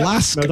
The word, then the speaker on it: LASK: Is that how you pronounce it, Ryan? – Yep, LASK.